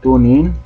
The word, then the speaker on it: TuneIn: Hay dos versiones del TuneIn Radio, así se pronuncia, TuneIn. Esta es la pronunciación correcta.